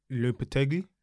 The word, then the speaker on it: Lopetegui: Lopetegui? – Got rid of him. – I butchered that, but still. – No, yeah, you said it right.